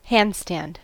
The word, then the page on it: handstand: American English